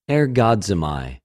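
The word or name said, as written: ἐργάζομαι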